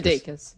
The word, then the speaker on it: Sudeikis: Sudeikis.